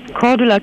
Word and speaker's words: Cordula: Cordula Kienes.